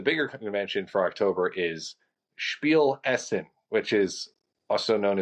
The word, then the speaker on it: Spiel: Spiel, I think I'm saying that right. I think it's with an SH kind of a sound. – Spiel Essen.